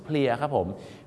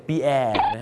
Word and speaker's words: pier: เพียครับผม P-I-E-R เพียร์แล้วออกเสียงว่าเพียนะครับบางคนนึกว่าปีแอร์นั่นมันชื่อคนฝรั่งเศสปีแอร์นะฮะอโศกปีแอร์ไม่มีนะครับอโศกเพียครับ